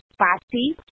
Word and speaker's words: Fati: Okay, so my name is pronounced Fati. capital F-A-T, lowercase I.